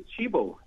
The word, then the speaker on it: Cibo: It's written C-I-B-O, but in Italian, the letter C, they pronounce it as C. So it is Cibo, Cibo meaning food in Italian.